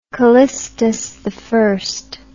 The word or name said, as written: callistusi